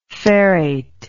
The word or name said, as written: ferrate